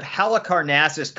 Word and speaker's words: Halicarnassus: Hey, before we jump into the news, we gotta give it up to Bub for that pronunciation of Halicarnassus correct on the first time through.